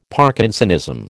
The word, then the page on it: Parkinsonism: American English